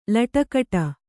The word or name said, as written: ಲಟಕಟ